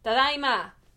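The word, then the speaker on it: tadaima (ただいま): Oh, because it's AI, so it's tadaima. – Tadaima. – Tadaima. – Tadaima. – Ai. – Ai. – Tadaima. – Tadaima. – You pronounce both of them, which is not something you do in English or in Spanish. Or in French.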